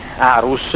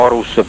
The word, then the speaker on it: Aarhus: Questo sostanzialmente è quanto afferma la convenzione di Aarhus, o Aarhus per chi fa il purista come pronuncia,